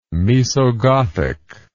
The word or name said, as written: Moesogothic